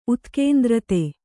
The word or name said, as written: ಉತ್ಕೇಂದ್ರತೆ